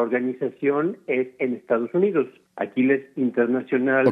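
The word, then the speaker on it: Achilles: La pronunciación correcta que nosotros utilizamos es Aquiles.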